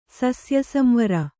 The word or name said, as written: ಸಸ್ಯಸಂವರ